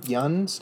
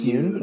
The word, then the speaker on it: Yeun: I was really excited to see how do you pronounce it? Steven Yeun? Yoon? – I think it's Yoon.